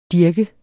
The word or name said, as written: dirk